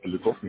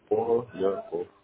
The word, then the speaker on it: Поляков: Поляков Михаил Юрьевич. – Поляков? – Да. – А, пишется правильно? – Нет, через О, как Поля. Поляков.